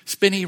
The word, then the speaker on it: Spinney: Spinney rhymes with skinny.